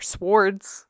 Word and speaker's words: sword: Her swords. Why am I pronouncing the W in sword all of a sudden?